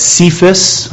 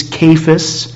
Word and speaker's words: Cephas: I think some people pronounce that Cephas. I think it's a hard C. I think it's Cephas, which means Peter.